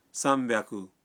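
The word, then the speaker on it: سام بیاکو (三百): لیکن بعض اعداد کا تلفظ کچھ مختلف ہے۔ مثلاً 300 ہے سام بیاکو، 600 ہے روپ پیاکو، اور 800 کو کہتے ہیں ہاپ پیاکو۔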